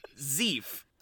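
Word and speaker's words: Zieff: We've got Howard Zeef directing today. – Hi, Howie. – Yeah, it is pronounced. Oh, there's even a pronunciation note. Thank you, Wikipedia. Zeef.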